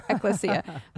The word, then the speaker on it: ecclesia: But it sounds to me like that word, what did we land on? Ecclesia. – Yeah. – That's the pronunciation.